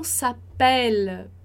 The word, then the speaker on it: s'appelle: Il, elle, on s'appelle, pelle. Por que que vai fazer esse som? Porque tem os dois L's. Então, duas consoantes depois do E faz com que ele seja pronunciado com som de E.